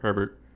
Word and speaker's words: Herbert: Herbert.